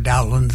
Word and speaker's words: Dowland: But most consensus now say that it's a short O, because there's a composer by the name of Cowper who's contemporary, and so Cowper, Dowland, something like that.